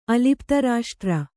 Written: ಅಲಿಪ್ತರಾಷ್ಟ್ರ

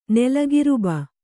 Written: ನೆಲಗಿರುಬ

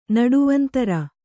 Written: ನಡುವಂತರ

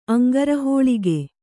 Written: ಅಂಗಾರಹೋಳಿಗೆ

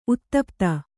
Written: ಉತುಪಾತ

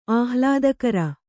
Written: ಆಹ್ಲಾದಕರ

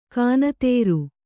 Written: ಖೂನದೋರು

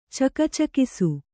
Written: ಚಕಚಕಿಸು

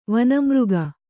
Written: ವನಮೃಗ

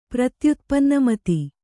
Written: ಪ್ರತ್ಯುತ್ಪನ್ನಮತಿ

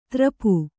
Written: ತರಪು

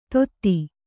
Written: ಧೋತಿ

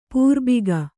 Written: ಪ್ರಭಾಗ

